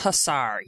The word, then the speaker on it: Hussar: HMS Hussar, yes. And it is in the Hellgate area of the East River. So the letter H this week, we are bringing you the Hellgate and the HMS Hussar.